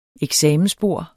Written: eksamensbord

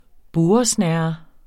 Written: burresnerre

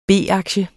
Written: B-aktie

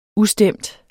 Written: ustemt

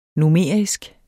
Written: numerisk

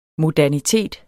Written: modernitet

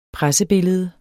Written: pressebillede

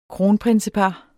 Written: kronprinsepar

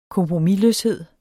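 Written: KOMPROMISLØSHED

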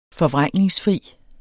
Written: forvrængningsfri

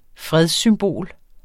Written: fredssymbol

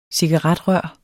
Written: cigaretrør

